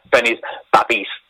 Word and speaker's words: Babbies: Am I saying it right? – Yeah, Benny's Babbies.